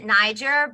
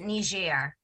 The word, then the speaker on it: Niger: the Americans will pronounce it Niger, but the rest of the world will pronounce it Niger.